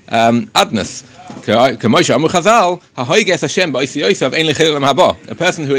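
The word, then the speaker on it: Adonai: but we pronounce it as Adnas, right? Adoin. So the pronunciation is Adnas, ki Hashem anechbad anoyua osele kroise b'kiksavoy, because as we know, you're not allowed to express the name of Hashem in the way it's written.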